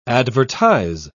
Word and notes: advertize: British English

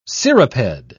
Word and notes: cirripede: British English

